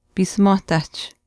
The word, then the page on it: piisimwaahtaahch: Chisasibi